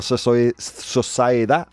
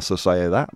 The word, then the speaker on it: sociedad: La monogamia no es realista en la sociedad moderna. Oof, I butchered that. I butchered it. Monogamia no es realista en la sociedad moderna.